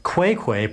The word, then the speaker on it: Xwayxway: About 100 years ago, there was a village called Quay Quay, but it's spelled X-W-A-Y-X-W-A-Y.